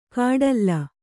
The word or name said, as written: ಕಾಡಲ್ಲ